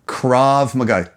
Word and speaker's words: Krav Maga: Krav Maga. K-R-A-V. – Right. Krav Maga. – Next word, Maga. – Krav Maga. – M-A-G-A.